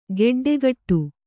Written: ಗಡ್ಡೆಕಟ್ಟು

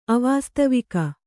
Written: ಅವಾಸ್ತವಿಕ

